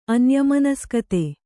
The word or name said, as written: ಅನ್ಯಮನಸ್ಕತೆ